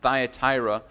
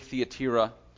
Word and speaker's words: Thyatira: This morning we're going to be looking at the church of a city, a town called Thyatira, right? Or pronounced Thyatira, in Greek, Thyatira.